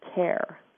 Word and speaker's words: Kerr: And that is spelled K-E-R-R, but it's pronounced Care.